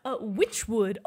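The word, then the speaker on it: Wytchwood: Witchwood.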